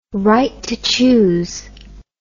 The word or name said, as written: right to choose